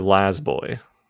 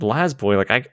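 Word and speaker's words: La-Z-Boy: Well, those are Laz Boy. People pronounce it wrong a lot, so I gotcha. – Get it. I'm sure I've told this story on the podcast, but when we got our Lazy Boy couches, there was a 3-seater, and then there was 2 with a divider, something mm-hmm. where you could put plates or whatever, some drawers. And as it was being brought in by the people, I was like, it's pronounced Laz Boy.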